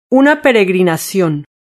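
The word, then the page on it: una peregrinacion: Spanish (Spain)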